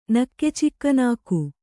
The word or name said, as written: ನಕ್ಕೆಚಿಕ್ಕನಾಕು